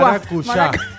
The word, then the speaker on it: maracuyá: Maracayúa. Maracayúa. – Maracuyá. ¿Cómo se dice? – Maracuyá. – Maracuyá. Novio para la mujer. Maracuyá o maracuyá. – Para mí, maracuyá. – Con acento.